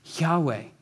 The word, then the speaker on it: Yahweh: And the ancient Israelites took this name, I am, and they turned it into a four-letter word that we pronounce as Yahweh. Yahweh. And anytime you see the capital L-O-R-D in Scripture, that's this covenant name that God has given himself of Yahweh.